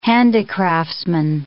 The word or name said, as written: handicraftsman